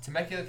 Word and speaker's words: Temecula: Temecula, California, man.